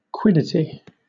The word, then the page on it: quiddity: British English